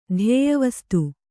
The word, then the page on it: ಧ್ಯೇಯವಸ್ತು: Kannada